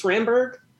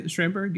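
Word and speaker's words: Schramsberg: I never say it right. It's Schramberg. – Okay, Schramberg, yeah.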